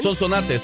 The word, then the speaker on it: Sonsonate: ¿Lo dije bien? – Sonsonate, sí.